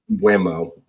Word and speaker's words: Mbeumo: You know, no Rico Henry, no Aaron Hickey, no Brian Mbeyo. I can't pronounce that name. – Mbeumo, yeah.